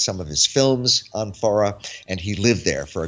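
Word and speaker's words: Fårö: Now, this is an island, the spelling looks in English like Pharaoh, but it's pronounced Farah.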